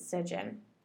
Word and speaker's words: Sigyn: That's her name. At least that's how I pronounce it. I don't know if everybody else pronounces it differently, but I've always pronounced it Sigyn. It's S-I-G-Y-N. So, Sigyn. Really cool chick.